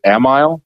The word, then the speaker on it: Amiel: The middle name is it's pronounced in the family as Amiel.